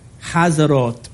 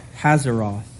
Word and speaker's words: Hazeroth: And they set out from Kibroth Hataava and camped at Hazeroth, or Hazeroth in English pronunciation.